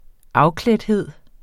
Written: afklædthed

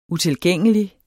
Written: utilgængelig

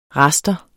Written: raster

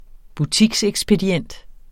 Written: butiksekspedient

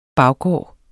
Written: baggård

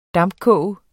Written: dampkoge

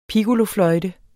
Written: piccolofløjte

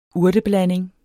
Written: urteblanding

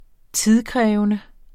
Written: tidskrævende

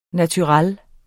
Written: NATURELLERNES

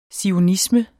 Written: zionisme

